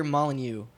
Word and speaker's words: Molyneux: All right, so one thing that all these games have in common is that they were all creations of Mr. Peter Molyneux.